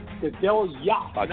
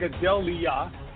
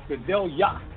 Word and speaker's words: Gadelha: Whenever you see the H and the A, it's pronounced like a Y-A. So it's Kladja Gadelha. Not Gadelha. Gadelha. Kladja Gadelha.